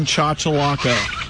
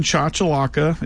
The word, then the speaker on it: Chachalaca: The plain chachalaca.